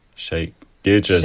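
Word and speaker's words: Shai Gilgeous: Shay Gillis. Wie wird denn der ausgesprochen? – Shay Gilgis.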